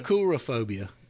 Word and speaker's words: coulrophobia: Coulrophobia. Isn't that the pronunciation? – Yeah. Coulrophobia. Coulrophobia. Okay.